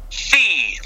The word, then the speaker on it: PHI: So PHI, pronounced fee, like F-E-E. Mm-hmm. That's how it's pronounced. P-H-I is pronounced fee.